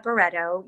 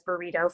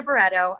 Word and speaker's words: Berredo: Don't even start with Berredo. I was Burrito for years. But my name is Roberta Berredo.